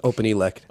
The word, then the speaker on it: OpenELEC: Am I saying that right? OpenELEC? – It's OpenELEC.